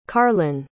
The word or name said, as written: carlynn